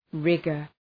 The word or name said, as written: rigor